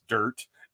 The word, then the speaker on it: Dyrt: I can pick it up at any time, but the dirt, it's DYRT, pronounced dirt, like DIRT.